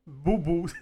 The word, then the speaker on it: Bohbot: Cette donnée est essentielle lorsqu'on sait à quel point l'hippocampe est important pour une cognition saine, nous explique Véronique Bobo. Tiens-toi bien, ça s'écrit B-O-H-B-O-T.